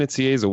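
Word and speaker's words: Nicieza: Fabian Nitziezza, welcome to the show.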